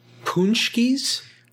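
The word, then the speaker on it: pączki: Punchkies? – Yeah, it's spelled P-A-C-Z-K-I.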